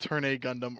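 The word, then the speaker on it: ∀ Gundam: it's pronounced Turn A Gundam or some shit. It's wild. Because otherwise it just looks like V, right? It looks like V Gundam.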